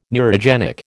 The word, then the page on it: neurogenic: American English